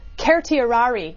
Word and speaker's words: certiorari: Will they issue a writ of certiorari or certiorari, or the actual correct Latin pronunciation, I am told by Latin professors, is certiorari, which nobody in the history of lawyers has ever used in their life.